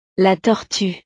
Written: la tortue

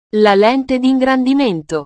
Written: la lente dingrandimento

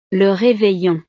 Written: le reveillon

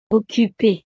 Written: occupee

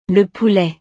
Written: le poulet

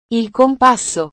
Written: il compasso

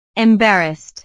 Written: embarrassed

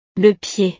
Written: le pied

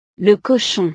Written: le cochon

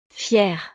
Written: fiere

